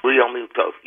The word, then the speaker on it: Realmuto: Oh, you said it right on the head with Realmuto. You know that? Realmuto.